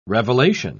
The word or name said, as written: revelation